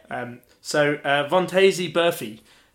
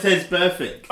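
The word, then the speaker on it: Vontaze Burfict: Vontaze Burfee. – No. – That's wrong? – I don't know how to say it. How do you say it? – Vontez Perfect.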